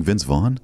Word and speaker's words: Vince Vaughn: And Vince Vloff is good in that. And then what else was he in, Garen? – He was in. – Are you mispronouncing Vince Vaughn? – I thought he was mispronouncing Rice.